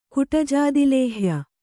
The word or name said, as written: ಕುಟಜಾದಿಲೇಹ್ಯ